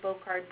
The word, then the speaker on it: Boccard: So my last name is spelled B-O-C-C-A-R-D. And the fund also has a Facebook page, which is the Lisa Bocard Breast Cancer Fund.